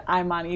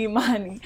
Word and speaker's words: Imani: It's the Midwestern twang. My folks grew up in the Midwest, so they said Imani, even though it's technically Imani.